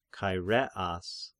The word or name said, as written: Chaireas